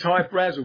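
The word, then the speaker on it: Hy-Brasil: There's Hyatt Brazel, High Brazil. People pronounce it High Brazil, and it's Brazil. Okay. – yeah, but it's High Brazil because that's how the Irish would pronounce it, because it's an it's an island that's off the southwest coast of Ireland.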